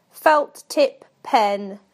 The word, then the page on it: felt tip pen: British English